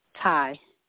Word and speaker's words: Tye: Ty. – I spell that? – T-Y-E. – T-Y-E, okay.